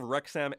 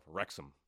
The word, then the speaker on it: Wrexham: What we learned, owner of Wrexham FC, Ryan Reynolds, will be awarded the Order of BC. Is he BC's most known actor? – Right now? – And I should clarify and correct myself. Wrexham.